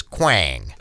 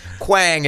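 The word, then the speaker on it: Quang: Now, I asked you before we started about the pronunciation, because for people listening, it's Q-U-A-N-G. And for me, as a white guy in Minnesota, the white guy way to say your restaurant is Kwong.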